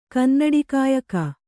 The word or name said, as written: ಕನ್ನಡಿಕಾಯಕ